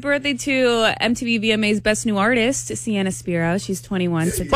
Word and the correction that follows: Spiro: Spyro. – Yes. Yeah, Sienna Spiro. – I've been saying it wrong this whole time. Spyro.